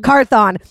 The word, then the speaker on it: Carthon: And now you introduced me to Jazzmine Carthon. Did I say your last name right? I am the queen of fucking up names. – It's Carthon. – Carthon.